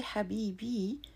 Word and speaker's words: Habibi: When you say Habibi, you have taken the word Habib, which is masculine, a male loved one, and added a long E at the end. This long E may sound short because it's unstressed, but grammatically it's a long E, and phonetically also.